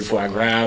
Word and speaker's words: foie gras: I made a spiced verju to go in the bottom of it, and the seared foie gras with a little crostini and stuff.